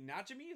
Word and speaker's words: Najimy: Kathy Najimy, is that how you pronounce that? – Yeah.